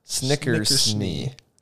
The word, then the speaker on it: Snickersnee: Snickersnee. – Interesting. – Wow, that's interesting. – Yes, and it's literally spelled Snickers and then N-E-E. Snickersnee.